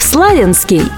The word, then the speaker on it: Славянск: Правильное ударение на «я». Вариант «Славянск» фиксируют и топонимические словари.